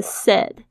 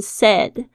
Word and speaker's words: said: Said to me 就是跟我说，那大家要特别注意一下这个 said，它是 S A Y 的过去式嘛，但是呢发音的部分大家要特别注意，不是 said，而是 said。这个 A I 的声音它是短音的这个哎哎的这个声音 said。